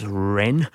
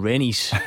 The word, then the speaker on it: Rennes: It's Rennes. I think John went for a more Bishop Briggs pronunciation in Rennies, which is sort of like the heartburn stuff.